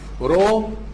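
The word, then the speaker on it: رو (ρω): الحرف اللي بعد كده حرف رو. شبه حرف البي الانجليزي لكن هي بتتنطق زي حرف الايه الراء او الار، كانك جيت رسمت حرف الار كده وقمت شايل الرجل ده.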